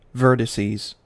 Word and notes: vertices: American English

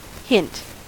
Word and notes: hint: American English

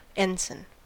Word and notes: ensign: American English

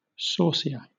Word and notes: saucier: British English

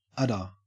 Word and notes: udder: Australian English

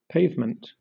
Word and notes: pavement: British English

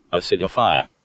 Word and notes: acidify: American English

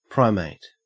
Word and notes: primate: Australian English

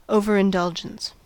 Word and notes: overindulgence: American English

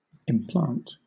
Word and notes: implant: British English

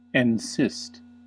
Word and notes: encyst: American English

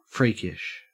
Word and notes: freakish: Australian English

